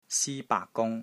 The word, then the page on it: SI1 BAAK3 GUNG1: Cantonese